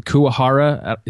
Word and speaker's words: Kuwahara: One of the coolest filters early on, I don't know if you're still as excited, is it the Kuwahara? Is that how you pronounce it? – Yeah, I believe it makes it look like a painting.